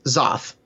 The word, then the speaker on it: Xoth: So, and then going on to number 23 is Zoth. – Our first Bandcamp pick. – First Bandcamp pick. Yes, that was obviously our X pick. It's spelled X-O-T-H.